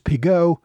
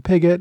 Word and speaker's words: Piggott: And while I'm at it, I, in some earlier episodes, mispronounced General Robert Piggott's last name as Pigot, which is the French pronunciation. Since the family's been living in Britain for several generations, it's become Piggott, and I should have known that.